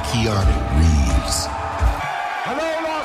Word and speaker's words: Keanu: Dit comment, Guérin Keanu. – Comme Kanye.